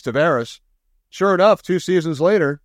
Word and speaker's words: Tavares: And he laughed and he said, hi, I'm John Tavares.